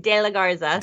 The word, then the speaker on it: De La Garza: Did I say it too quickly or? – No, I think you said it great. De La Garza.